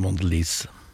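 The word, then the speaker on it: Mondelēz: Uh, eh, Mondelis har jo uh, Heter det Mondelis? Ja, vet du hva. Har, det er et amerikansk selskap. Jeg hører at det blir uttalt på mange forskjellige måter i medier. Men nå har jeg sjekka. Jeg har gått til kilden til dette amerikanske selskapet utenfor Chicago. Og de kaller det Mondelis.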